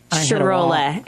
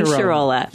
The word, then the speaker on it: Shirola: Shirola. Amy Shirola. – Shirola. Like you said to remember it how? – My Sharona, but my Shirola.